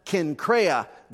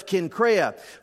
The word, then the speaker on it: Cenchrea: Now, that word is actually pronounced Cenchrea. The C is hard, but I didn't get a chance to tell you that ahead of time, so I said it the old way. But it's Cenchrea.